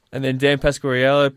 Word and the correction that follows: Pasquariello: Penn State, or Pasquarello, I think we've now established his name is. We've been saying it wrong all year, I think, Lachie.